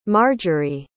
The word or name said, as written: marjory